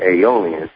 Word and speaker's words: Aeolian: Interesting about Aeolian is the way it's spelled and the way it sounds. It sounds like the word alien if you were to say it really fast.